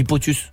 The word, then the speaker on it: POTUS: POTUS, President of the United States, on dit POTUS.